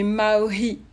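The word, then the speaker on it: Mā'ohi: Tu chantes parfois en langue vernaculaire tahitienne, dite maui. Je pense que je prononce bien. – Presque, presque. En fait, nous, on prononce le H. Du coup, ça fait maori.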